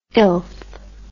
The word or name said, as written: illth